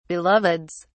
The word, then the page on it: beloveds: British English